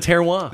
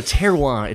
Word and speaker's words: terroir: It's the terroir. – There you go. – Exactly. I was going to take the long way there. So terroir, it's this French concept of place.